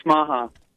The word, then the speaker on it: Smaha: It's Smaha. – Okay. – Spell that. – S-M-A-H-A.